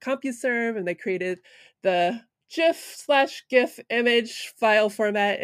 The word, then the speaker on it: GIF: It is a soft G pronounced GIF in the story.